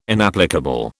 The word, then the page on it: inapplicable: British English